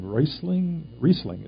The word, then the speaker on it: Riesling: And best of all, complimentary wine, Riesling is how you pronounce it, yeah, and Burgundy.